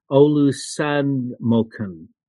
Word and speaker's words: Olusanmokun: Uh, uh, I I think I looked up actually how to pronounce his name, but it's Babs Olu Uh, yes, O Olusanmokun. Um, it's actually almost pronounced exactly the way it's spelt.